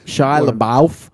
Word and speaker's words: Shia LaBeouf: Is that how you say his name? Shia LaBeouf. – Yeah.